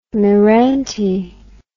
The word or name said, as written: meranti